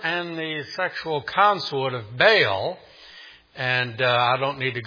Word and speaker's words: Baal: Notice the name Baal, or technically in Hebrew, Baal. You may have one of those Bibles that puts it in different syllables with an accent. So it's Baal, or we'd say Baal.